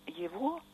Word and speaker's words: его: Почему мы произносим его, и очень трогательно иногда читать в письмах людей, которые, ну, не имели возможности, наверное, получить какой-то уровень грамотности, я его видела. То есть произносим мы его, а пишем его. Или, например, ничего нового, и пишем мы нового.